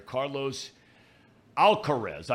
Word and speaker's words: Carlos Alcaraz: Open last year, Carlos Alcaraz, I think is how you pronounce his name, right? – Yep.